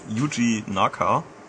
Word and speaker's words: Yuji Naka: Yuji Naka.